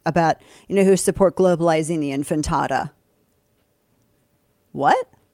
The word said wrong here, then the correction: intifada: I think it's pronounced the intifada. I think you're saying it. – Did I say it correctly? – I think you're saying infantata. – Oh, I did. Intifada, yes. Intifada.